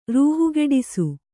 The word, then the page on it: ರೂಹುಗೆಡಿಸು: kannada